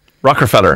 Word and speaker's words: Rockefeller: I always get confused with where the ER goes. – Rockefeller. – Rockefeller. – Oh, yeah. It's Rockefeller. – Rockefeller. – There's no ah.